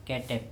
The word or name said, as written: kETEp